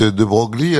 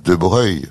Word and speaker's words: Broglie: Mais je comprends, vous dites de Broglie, alors donc. – Oui. – Abruti. – Laurent, c'est pas vrai parce que vous. – Normalement, dites... – les gens de famille disent De Breuil.